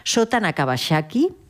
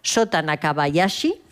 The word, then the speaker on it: Shota Nakabayashi: Se llama Shota Nakabayashi. No, Shota Nakabayashi. Ahora lo he dicho bien, que me tengo que embalar para decirlo bien.